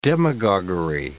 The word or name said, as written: demagoguery